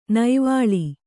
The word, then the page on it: ನೈವಾಳಿ: naivāḷi